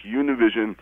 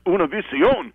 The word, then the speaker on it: Univision: So last week when you were interviewing Kat Kamick and she was ribbing you and you said you watch Univision, I practically fell out of my beanbag chair. I mean, you really got to brush up on your Mexican pronunciation. It's Univision.